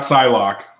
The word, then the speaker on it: Psylocke: Yeah, not Psylocke, as some idiot said. – Psylocke, yeah. The S is silent. Or P is, I don't know.